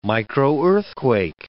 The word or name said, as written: microearthquake